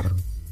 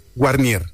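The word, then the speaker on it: Warnier: Es que la W es alemán, ¿no? – Es alemán, exactamente. – Claro, sí. Es que cuando los alemanes la pronuncian como si fuera la B corta, los británicos o los anglosajones la pronuncian Guarnier, podríamos decirlo de alguna manera.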